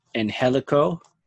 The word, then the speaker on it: Angelico: Next matchup on the card, you had the Dark Order versus Angelico.